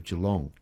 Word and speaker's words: Geelong: We have very little time, but I have to say I was very impressed, Marianne, with your pronunciation of Geelong.